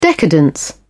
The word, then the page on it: decadence: British English